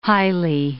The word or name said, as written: highly